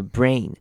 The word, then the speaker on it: brain: 好，注意到 faulty 这个字，这边的 au 会念成短母音 o 的声音，r 的声音，faulty faulty，所以不用念成 a 或 u 本身的声音，faulty caused by faulty mood regulations regulations in the brain。好，brain 这个字也是台湾人非常容易念错的字，因为它 ai 这边要念成长母音 a 的声音，所以记得要把 a 的声音发完，它比较像是 a 再加一个 e 的声音 a。